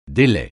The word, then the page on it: délai: français